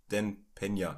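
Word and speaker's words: Dan Peña: Dan Pena geguckt, ne? Ja. Habe ich richtig ausgesprochen? Ja.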